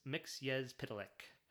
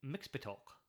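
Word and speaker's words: Mxyzptlk: Mixpitolk. Of course, in every incarnation for a couple of decades now, it's Mixyezpitalik, but I've never been a fan of Mixyezpitalik in terms of the pronunciation of Mixy.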